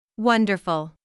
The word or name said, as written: wonderful